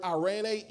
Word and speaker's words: eirene: That word peace in the Greek is the word irene, E-I-R-E-N-E, like Irene. Y'all know the Irenes? Irene is properly pronounced.